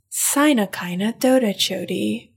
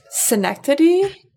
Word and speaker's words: synecdoche: Our favorite is Sinakina Dodachodi, which is synecdoche, which to this day, I've been saying that I've been saying the non-word word for months, and I still can't remember what synecdoche means.